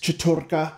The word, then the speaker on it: Chattur'gha: Either there, there's Chattur'gha, Ulyaoth, and Xel'lotath.